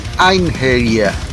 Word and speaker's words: Einherjer: Einherjer.